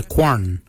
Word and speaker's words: gwan: Kualak is spelled G-W-E-L-Y-A-G. Kualak. Kualak is your hips. Your backside is your quan. Your quan is spelled G-W-A-N. Quan is your backside. Thigh. We say dal. Dal. Dal is spelled D-H-A-O-L-Y.